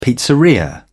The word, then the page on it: pizzeria: British English